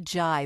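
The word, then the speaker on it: Zhai: Cynthia Jai, but it's spelled Z-H-A-I, I believe.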